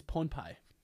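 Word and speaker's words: Pohnpei: Pohepai Day. – No, I think it's Ponpai. I've changed the pronunciation. – How do you spell it? – Ponpei, Liberation Day.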